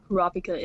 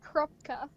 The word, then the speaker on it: Kurapika: Although I've always pronounced it Krupka. I don't know why. – Krupka? – Yeah. – What? You just ignore the A in his name? – Yep.